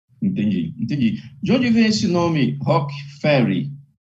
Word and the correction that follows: Rogue: quase um pouquinho. É Rogue Ferry, quase, na verdade. Rogue.